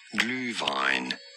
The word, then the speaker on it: Glühwein: The German word for it is pronounced Glühwein. Glühwein.